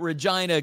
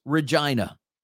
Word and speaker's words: Regina: And you do pronounce it. I was calling it Regina because it was more funny saying that. It's not Regina, like what would be someone's name. It is Regina.